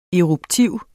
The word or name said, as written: eruptiv